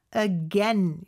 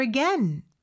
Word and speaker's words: again: again 발음은요. again이 아닙니다. again 이렇게 에 발음으로 해주셔야 돼요.